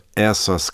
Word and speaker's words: essas: If you have one S between vowels, you pronounce it like a Z. And so, essas clásicas, S sound. And if you're a Spanish speaker, it seems weird to see two S's, but that's why there are two S's there in Portuguese.